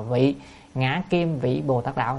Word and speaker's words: vị ngã kim vị Bồ Tát đạo: chữ đó phải đọc là vị ngã kim vị Bồ Tát đạo, là con nay vì Bồ Tát đạo, chứ không phải là con nay hành Bồ Tát đạo.